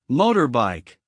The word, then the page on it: motorbike: American English